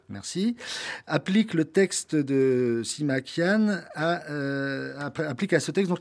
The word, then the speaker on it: Sima Qian: La première tentative sérieuse de relater la vie du maître, vous le rappelez, donc revient à Sima Qian. Vous me corrigez si je prononce... – Sima Qian.